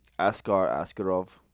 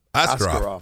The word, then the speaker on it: Askarov: How do you say this guy's name? – Askarov. – Askarov. That's it.